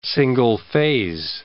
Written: single-phase

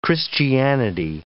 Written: Christianity

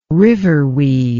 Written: riverweed

